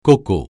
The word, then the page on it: coco: francais